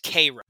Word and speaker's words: Cairo: It's like a famous place, but I kind of eye roll that Storm, the Egyptian goddess, finds herself in Cairo, Illinois, which I believe is actually pronounced Cairo.